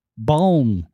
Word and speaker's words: balm: Doing silent L's. Is there an L in stick? – Lip gloss? – Well, come on. – What? – Lip balm.